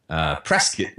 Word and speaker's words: Prescott: We were talking before this interview about the fact that right now we're up in Prescott, which sounds like biscuit, but it's spelled like Prescott in Arizona.